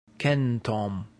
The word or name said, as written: centum